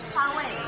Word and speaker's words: Huawei: Ah, la pronunciación correcta es Huawei.